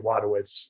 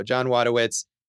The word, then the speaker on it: Wojtowicz: The actual guy's name is John Wojtowicz. I don't know. I'm probably mispronouncing that. – Well, I think it's like Wadowitz. – There you go. John Wadowitz.